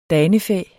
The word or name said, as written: danefæ